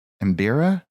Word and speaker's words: Mbira: But on the electric Mbira, is that am I saying it right? – That's right. You are.